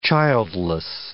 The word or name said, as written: childless